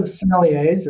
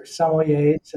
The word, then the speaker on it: sommeliers: Now, the world of sommeliers or sommeliers, and it's pronounced differently around the world, has gone through a dramatic change.